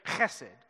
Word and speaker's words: hesed: Now, really, you don't say it like that. You've got to spit when you say it. Right here? It's a Hebrew word. You say hesed. Can you say that?